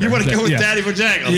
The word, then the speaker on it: Tatty Bojangles: It's not Taffy. – It's Tatty. – Taffy. – Bojangles. – Tatty. Oh, am I saying it right? It's Taffy. – Yeah. Yeah. You know, tit for tat.